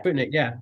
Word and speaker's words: Budik: played by Edya Budik. Am I saying that correctly? – Yeah, Budik.